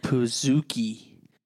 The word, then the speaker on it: Pizookie: Pizookie. Maybe it's a pan cookie. – But then why would it be piz? – It's not. It's puh. Zookie.